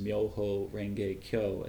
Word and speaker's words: Myoho Renge Kyo: And he explains the Lotus Sutra by looking at the five characters of its name in Chinese, Myoho, Renge, Kyo, as the Japanese pronounce it.